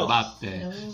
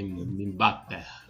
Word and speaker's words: Mbappé: Mbappé. Eu botei aqui como se pronuncia, eu acho que parece que é Mbappé.